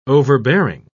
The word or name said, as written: overbearing